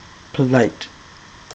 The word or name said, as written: polite